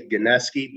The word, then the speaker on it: Gineski: We say Gineski, but like, doesn't make sense phonetically, so it's however you want to interpret it, man.